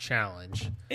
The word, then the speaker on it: Challonge: I want to say it's pronounced challenge. – It's spelled challenge. – Challenge. How the French say it.